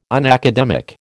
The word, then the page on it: unacademic: American English